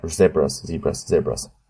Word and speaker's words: zebras: Zebras. Whatever, I'm going for American pronunciation. Crossing Zebras. – Depends on which side of the Atlantic you're on.